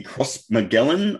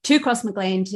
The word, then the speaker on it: Crossmaglen: the Crossmaglen, I'm going to pronounce it wrong, youth centre. – Crossmaglen, way off.